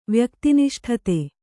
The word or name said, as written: ವ್ಯಕ್ತಿನಿಷ್ಠತೆ